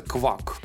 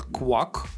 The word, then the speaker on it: Kwak: Это пиво называется квак. Пишется как Гордость Кей... Бельгии, кстати. – Да. да. Оно пишется как K W A K, вот квак, такое так, так называется.